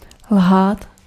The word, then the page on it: ljuga: /ˈjʉːˌɡa/